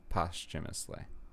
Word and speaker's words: posthumously: Yes, if you try to sound it out phonetically, you'd be... – Posthumously? – Yeah, you'd be likely to say that, but yeah, posthumous, I would say.